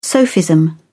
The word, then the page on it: sophism: British English